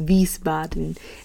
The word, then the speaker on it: Wiesbaden: It is pronounced Wiesbaden in Germany, which is where Denisha was stationed at for the remainder of her time there.